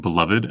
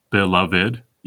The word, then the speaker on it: beloved: Friends, am I alone in pronouncing beloved as three syllables, beloved, even in my head?